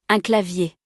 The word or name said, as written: Un clavier